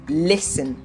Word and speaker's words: listen: Now, there are 2 ways people mispronounce this word. It's either they try to say listen, you know, trying to say every single letter in the word, or they say listen. Neither of them is correct.